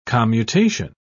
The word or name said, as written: commutation